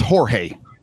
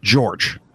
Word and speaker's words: Jorge: That's Jorge, not George.